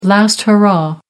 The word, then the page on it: last hurrah: American English